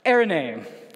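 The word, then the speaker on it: Eirene: Erene. There, that's for those watching online. I just heard from a biblical professor how to say this correctly. Erene.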